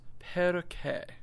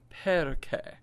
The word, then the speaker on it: perché: If you're looking at the paper, and it's the second verse where it looks like perci, but that's pronounced perche. C-H-E in Italian is che. So say it after me. Perché.